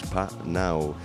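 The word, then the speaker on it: Pnau: como este que le da Dua Lipa y P-Now, antes he dicho P-Now, pero bueno, la pronunciación la he buscado ahora y es P-Now, trío australiano de dance music, también música electrónica, en fin, muy buena esta canción, con la que continuamos el programa Cold Heart.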